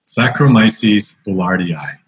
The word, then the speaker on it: Saccharomyces boulardii: Saccharomyces boulardii. And Saccharomyces, they don't usually spell out. But Sacro means sugar, and it's spelled S-A-C-C-H-A-R-O. Myces, M-Y-C-E-S. I'm not great at spelling like that. That's okay. You'll get us in the ballpark. We can Google the rest. – The rest of the word is called boulardii, and it's spelled B-O-U-L-A-R-I-I.